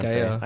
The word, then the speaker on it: Zendaya: He directed a movie called Malcolm and Marie, starring John David Washington and Zendaya. One word, Zendaya or Zendaya? I have no idea. I always say Beyonce instead of Beyonce. – Zendaya.